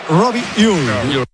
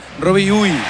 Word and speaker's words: Robbie Ure: A ver, el club pasó incluso un audio, lo ha pasado. Y en concreto sería Robbie Ure.